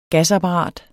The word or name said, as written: gasapparat